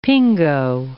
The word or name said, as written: pingo